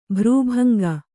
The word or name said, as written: ಭ್ರೂಭಂಗ